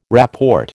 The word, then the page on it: rapport: American English